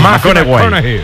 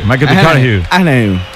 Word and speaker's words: McConaughey: Mateo McConaughey. – Sí, sí, sí, sí. – Es que hay que ir corrigiendo la pronunciación. – McConaughey. McConaughey. Bueno, pues el Mateo.